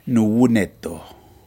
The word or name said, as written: nuwuneto